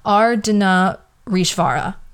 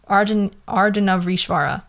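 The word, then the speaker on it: Ardhanarishvara: And then the infamous androgynous god, I want to re-pronunciate the name. So it's Ardhana Rishvara. Ardhana Rishvara.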